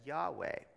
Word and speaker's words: Yahweh: that is actually the translation of the letters YHWH. Often pronounced in English as Yahweh,